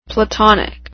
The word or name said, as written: platonic